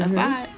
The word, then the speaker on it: Assad: Is that how you say it? Mm-hmm. Assad? Yeah. Their airfield over their chemical attack,